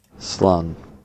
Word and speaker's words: Slán: Slán. – Slán. – Slán. Yeah. So Slán. It rhymes with Sean, but like it's pronounced differently because it's a different word.